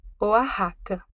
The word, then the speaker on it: Oaxaca: H-U-A-J-E. Fecha aspas. Que é uma planta comum na região. Mas não se engane com a pronúncia. A gente escreve Oaxaca, com X, mas fala Oaxaca.